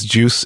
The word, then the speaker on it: Guice: Juice is, oh, and I should say it's Juice pronounced, it's spelled G-U-I-C-E if you're Googling it. Mm-hmm. So it's Juice and it's pronounced like GIF.